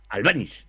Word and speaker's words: Albanis: Cristos, Cristos, lo he dicho bien, ¿no? Eh, eh Balmises. Albanis. Eh, Albanis. Albanis, sí, griego, el chico y que la verdad eh, me ha demostrado que, bueno, es un jugador a tener en cuenta.